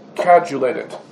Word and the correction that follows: coagulated: Basically, mayonnaise. Something, I said that correctly? How do you say it? Coagulated.